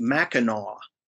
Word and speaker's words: Mackinac: And this is it's really strange, but you were pronouncing it Mackinac Island, and it's actually pronounced Mackinaw.